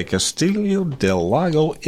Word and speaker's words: Castiglione del Lago: Now, Pastor Palombaro was born in, I know I'm not going to pronounce this correctly, Castiglione del Lago, Italy. – Close enough. – Have I got it? – Yep. – Fairly close? Okay, Castiglione del Lago, Italy, and raised as a practicing Roman Catholic.